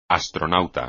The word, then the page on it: astronauta: espagnol